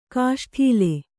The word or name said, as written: ಕೇಸೊಡಲ